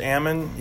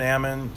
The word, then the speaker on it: almond: And he goes, well, they're pronounced, when they're in a tree, it's pronounced almond. – Yeah. – And when they're on the ground, it's pronounced almond, because to get them from the tree to the ground, you have to knock the L out of them.